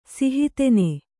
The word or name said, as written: ಸಿಹಿತೆನೆ